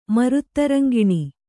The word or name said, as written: ಮರುತ್ತರಂಗಿಣಿ